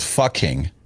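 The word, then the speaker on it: Pho King: Fucking. Yeah. P-H-O, King, and it's pronounced Fucking.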